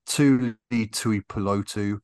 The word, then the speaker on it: Tuli Tuipulotu: Jalen Carter, Brian Branch, Tuli Tuipulotu.